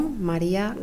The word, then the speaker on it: María: Me llamo María García Palomo. – ¿Cómo se escribe su nombre? – M-A-R y con acento A. G-A-R-C y con acento A. P-A-L-O-M-O.